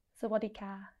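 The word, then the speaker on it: Sawadika (สวัสดีค่ะ): Sawadika.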